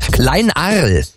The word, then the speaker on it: Kleinarl: Heißt, habe ich das richtig ausgesprochen? Kleinarl. – Ja, Lars, das ist richtig.